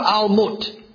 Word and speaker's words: Alamoth: And so therefore, at the beginning of the psalm, we see that this song is to be presented according to Almut, which is the proper pronunciation, although it may look like Alamoth in your Bible.